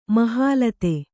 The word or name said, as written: ಮಹಾಲತೆ